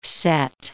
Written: Sete

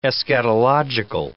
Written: eschatological

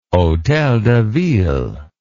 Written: Hôtel de Ville

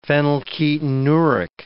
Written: phenylketonuric

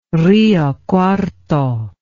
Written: Rio Cuarto

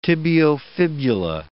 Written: tibiofibula

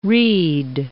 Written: read

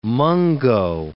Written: mungo

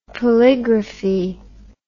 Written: polygraphy

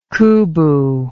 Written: cooboo

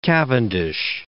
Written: Cavendish

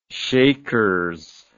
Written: shakers

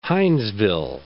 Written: Hinesville

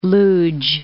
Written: Lodz

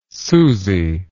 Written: susie

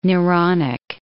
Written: Neronic

